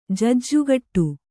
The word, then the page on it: ಜೂಜುಕಟ್ಟು: jūju kaṭṭu